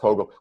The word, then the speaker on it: POGIL: it's pronounced Pogil.